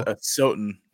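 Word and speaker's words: Asotin: There was a tournament in Assateton. Am I saying that right? – Asseton? No, you are not. That is Asoton.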